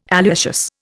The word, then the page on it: alliaceous: Indian